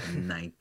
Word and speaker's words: Nike: Well, with Nike, you give it an extra syllable. – Yeah. Nike.